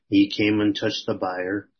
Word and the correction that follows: bier: That's pronounced beer.